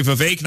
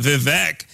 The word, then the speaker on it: Vivek: His name's actually Vivek, not Vivek.